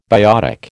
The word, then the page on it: biotic: American English